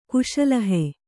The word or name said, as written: ಕುಶಲಹೆ